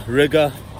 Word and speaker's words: Riga: I'm in Old Town Riga, which rhymes with nigga.